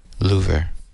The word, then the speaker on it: Louvre: Or French pronunciation, Louvre.